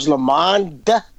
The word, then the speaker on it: LaMond: I love that pronunciation on the D. He said George LaMond and George Anthony.